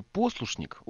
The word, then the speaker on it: послушник: Но кто, кроме Довлатова, знал, что в слове «послушник» ударение падает на первый слог?